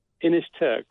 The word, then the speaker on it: Inishturk: So did I say this right? You are the chairperson of the island and it's pronounced Inishturk? – Inishturk. That's right.